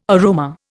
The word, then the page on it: aroma: Indian